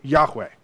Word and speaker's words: Yahweh: In the English Bibles, when you read the capital letters, capital letters, L-O-R-D, it's the Hebrew characters Y-H-W-H, Yod-Heh-Vav-Heh. Okay? It's called the Tetragrammaton. Okay? Just four letters put together. In Hebrew, you technically, if you were pronouncing the letters, would pronounce it Yahweh.